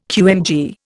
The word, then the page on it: QMG: Indian